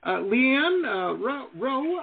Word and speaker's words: LeeAnne Rowe: Leanne Rowe, I think you pronounced.